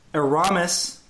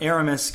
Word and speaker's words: Aramis: Um, Aramis Garcia, not Aramis Garcia, Aramis uh, Garcia.